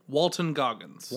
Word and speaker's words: Walton Goggins: Walton, not Walter. – Walter? – Walton Goggins. – Walter Goggins? – Walton. – Walton. Sorry, pretty sure it's Walton.